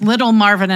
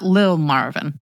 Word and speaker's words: Little Marvin: And I was like, so it's Little Marvin and not Lil Marvin. – I mean, I don't know this man. His, like, Lil Nas X is spelled L-I-L Nas X. this man's name is literally L-I-T-T-L-E. So I would assume that his cousins and them call him Lil Marvin, but the internet has his name as Little Marvin.